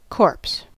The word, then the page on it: corpse: American English